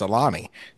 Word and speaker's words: Ilani: It's L-L-A-N-I. – Now I know it's Ilani. – It's actually pronounced A-L-A-N-E.